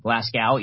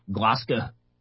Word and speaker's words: Glasgow: It's not Glasgow, even though that's how it's spelled. It's Glasgow. So in any case, this is my favorite story of the day about what's happening over in Glasgow.